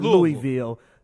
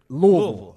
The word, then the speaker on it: Louisville: First of all, say it right. It's not Louisville. Louisville.